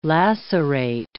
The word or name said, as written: lacerate